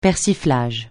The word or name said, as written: persiflage